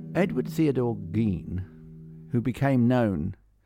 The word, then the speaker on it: Gein: Now, I have been mispronouncing his name, so I must come clean immediately. His name is Ed Gein. Edward Theodore Gein, although it's spelt G-E-I-N. – We thought it was Gein. – I did. – But it's Gein.